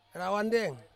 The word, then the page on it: rawandeng: Ansus